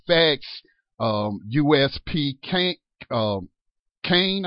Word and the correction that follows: Canaan: I believe is how you pronounce it. Kane. Canaan. Yes. All right, Canaan.